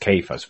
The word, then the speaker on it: Cephas: Cephas which is the Aramaic, or actually it should be pronounced Cephas, but is the Aramaic word that means the rock.